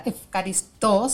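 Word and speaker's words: Efcaristo (ευχαριστώ): Después viene, por ejemplo, Efcaristo, que es Efcaristo sería con acento en la O, que es gracias.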